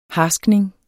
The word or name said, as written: harskning